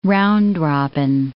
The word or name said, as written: round-robin